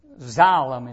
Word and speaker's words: xylem: X-Y-L-E-M. Xylem is how it's pronounced. It's part of what happens with photosynthesis when you study that subject. And xylem in a tree, it's a tissue, and it's called X-Y-L-E-M. It's pronounced xylem.